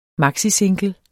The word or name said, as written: maxisingle